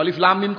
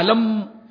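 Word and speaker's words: الم: بلکہ قرآن کریم میں تو ہم ہجے کر کے پڑھتے ہیں، تو الف لام میم کو پڑھنا چاہیے علم، لیکن علم نہیں پڑھتے، الگ پڑھتے ہیں حروف تہج الف لام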